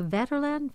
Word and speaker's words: Vaterland: It's revealed to the reader, if not to Laurel and Hank, that Walter was on a ship, the Vaterland, am I saying that correctly? – Yeah.